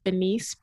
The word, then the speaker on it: Phenice: It's Bethany, B E T H A N Y dot Phenice, P H E N I C E.